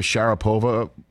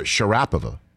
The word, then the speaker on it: Sharapova: Open that Russian athletes, you put the accent on the middle syllable. So remember we always say Maria Sharapova? We should call her Sharapova.